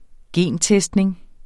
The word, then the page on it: gentestning: ˈgeˀn-